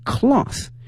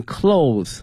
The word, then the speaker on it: cloth: In addition to avoiding plastic bag 塑料袋 plastic bag in favor of cloth one，这里面讲到这个布袋哈，这个布袋我们用这个 cloth， cloth C-L-O-T-H。那么这一次大家千万不能念成 clothes， clothes 的话呢表示衣服的什么什么东西了，如果把衣服后面这个 es 去掉的话呢，它的意思呢也发生了变化，同时呢发音也随之呢发生变化，从原来的这个 clothes 衣服那变成了 cloth C-L-O-T-H，把 es 去掉，同时呢发音从原来的哦变成了 o 音啊，希望同学们一定要注意了。